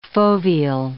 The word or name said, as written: foveal